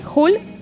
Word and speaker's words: Jul: Se escribe J-U-L, Hul. Hoffman con H-O-J-M-A-N y Las Moiras como Las Moiras.